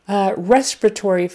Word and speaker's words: respiratory: So it's on the first syllable and then the fourth. But the strongest is on the first syllable. Respiratory.